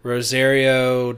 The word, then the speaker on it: Rosario: Rosario Dawson.